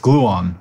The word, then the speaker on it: Gluon: They announced something called the OpenAI Ecosystem with Gluon.